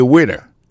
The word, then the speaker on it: waiter: I am a waiter. Ça veut dire moi, son garçon m'a servi dans le restaurant. Alors moi, je prononce le waiter. Waiter, ça veut dire accent tombé sous premier syllabe là. Waiter. W-A-I-T-E-R.